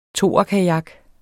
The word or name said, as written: toerkajak